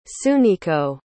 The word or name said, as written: tsuneko